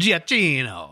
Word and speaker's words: Giacchino: Because I know Michael, I don't even know how to pronounce it, Giacchino? – Giacchino.